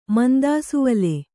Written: ಮಂದಾಸುವಲೆ

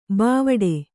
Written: ಬಾವಟೆ

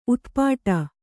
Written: ಉತ್ಪಟ

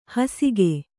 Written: ಹಾಸಿಕೆ

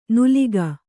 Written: ನೂಲಿಗ

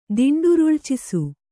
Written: ದಿಂಡುರುಳ್ಚಿಸು

